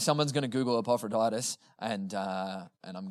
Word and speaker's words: Epaphroditus: Paul's writing, the reason why Paul is writing is, I'm going to get this name wrong, Epaphroditus. Did I get that right, Reese? No, he did, yes.